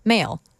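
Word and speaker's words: M(h)aol: And before that was IBS by the band Male, that is spelled M parentheses H end parentheses AOL parentheses pronounced Male end of parentheses.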